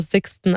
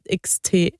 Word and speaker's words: SXTN: Ich denke mal Sixten, also S-I-X-T-E-N geschrieben. Die Band schreibt man ja S-X-T-N. – Sixten.